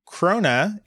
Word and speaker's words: Krona: As another fellow Swede, you got the closest on your second attempt at pronouncing the currency, but the K sound still sounds kind of funny. If for some reason you need to try and pronounce it again, just say Swedish crowns. Krona means crown after all.